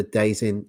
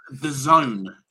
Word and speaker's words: DAZN: We are not part of the Dazin. Is it, how do you say it? Is it Dazin? – It's actually pronounced The Zone. It's like an abbreviated. It's actually The Zone.